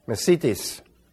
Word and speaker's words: mesites: It's spelled M-E-S-I-T-E-S, mesitis.